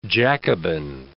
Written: Jacobin

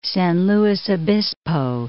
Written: San Luis Obispo

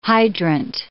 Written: hydrant

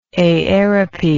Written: aerope

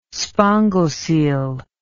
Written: spongocoel

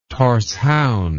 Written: thorshavn

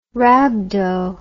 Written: rhabdo

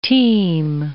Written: teem

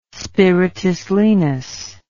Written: spiritus lenis

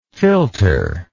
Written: filterer

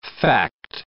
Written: fact

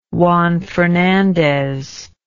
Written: Juan Fernandez

